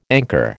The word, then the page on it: anchor: American English